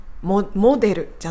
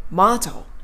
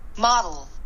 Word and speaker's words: model: モデルじゃなくて、マード。ちょっと辞書の発音聞いていきますね。これ、ウィズダム英和辞典の発音です。マード。モデルじゃないでしょマードみたいな感じで聞こえたと思うんですね。